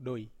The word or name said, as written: doi